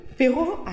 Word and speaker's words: Beraud: Beraud, I'm so sorry.